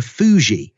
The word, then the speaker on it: Fuxi: And now in China, surnames go all the way back to, I think, 2,500 years BC, and they were first imposed by the Emperor Fuji, and F-U-X-I, you pronounce that.